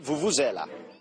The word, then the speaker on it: vuvuzela: Now, it's Confederations Cup time, and if you're in South Africa, whether you like it or not, you got to do the vuvuzela. The Confed... – Vuvuzela, that's how you pronounce it.